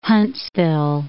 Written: Huntsville

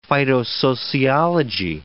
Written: phytosociology

